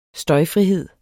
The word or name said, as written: støjfrihed